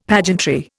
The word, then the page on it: pageantry: Indian